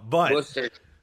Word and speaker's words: Worcester: But Worcester.